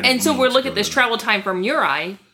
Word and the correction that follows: Uriah: Sorry, did I say that right? Uriah. I did not say it right.